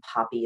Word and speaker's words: Poppe: And my name is Mia Poppy, and Poppy is spelled P-O-P-P-E.